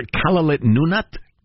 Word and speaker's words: Kalaallit Nunaat: I really prefer Kalaallit Nunaat, which is the Greenlandic pronunciation, if you don't mind.